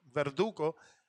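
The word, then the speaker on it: Verduzco: That being said, Ivana Verduco, I guess the Z is silent, Verduco, she got her first win, and you know her as Right Hook Roxy, so she was able to pick up her win.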